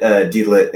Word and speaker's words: Deedlit: The name looks like Deedlit, but it's pronounced Digit. – That's what it's called. I haven't seen the anime in a while, so I forgot the exact pronunciation. I mean, even though, like, I don't even think they got the original voice actress voicing these characters. – Yeah, they pronounce it Deedlit in the anime, because I've been watching that the last couple of days.